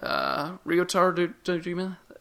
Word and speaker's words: Ryotaro Dojima: I'm going to butcher his first name. Ryotaro Dojima? Is that it? – Mm-hmm. – Okay, yeah. – Ryotaro, yeah.